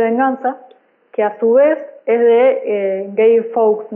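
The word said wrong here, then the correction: Guy Fawkes: no sé si está bien pronunciado. Guy Fox. Guy, Eh, este señor era parte de la conspiración de la pólvora en 1605.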